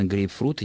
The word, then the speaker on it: грейпфрут: И, конечно, грейпфрут, и никаких ударений и вариаций здесь быть не может в принципе. – Ну, видимо, вот составитель словаря предполагал, что в этом слове ударение должно ставиться так же, как оно ставится в английском языке. Возможно, в английском языке это слово и должно произноситься с ударением на первом слоге.